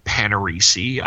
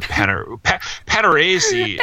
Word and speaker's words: Panarese: I've heard Panarese. I've heard Panarese. And that's what some people are like. Do you enunciate it in the Italian way? I said, no, unfortunately, it's been Americanized over the years.